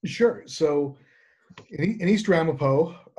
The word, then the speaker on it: Ramapo: Rampart. I always say it wrong. What is it? Okay. – It's the East Ramapo Central School District. – I'm always saying Arapaho.